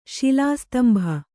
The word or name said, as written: ಶಿಲಾಸ್ತಂಭ